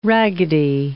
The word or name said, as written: raggedy